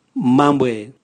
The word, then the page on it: mambwae: Raga